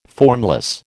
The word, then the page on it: formless: American English